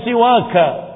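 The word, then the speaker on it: siwaka (سواك): Ya Rabbi la arjulahum siwak, ya Rabbi famna' minhum hamak. Enggak begitu bacanya, tapi pakai alif itu supaya dibaca ka. Ya Rabbi la arjulahum siwaka, ya Rabbi famna' minhumu hamaka.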